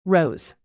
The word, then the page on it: rose: American English